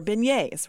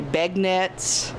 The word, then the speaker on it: beignet: It's probably the most mispronounced word, because it's B-E-I-G-N-E-T-S. So a lot of people say begnets, big nuts. We've heard some really crazy things through the years of how people pronounce it, but it's really beignet.